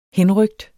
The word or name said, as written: henrykt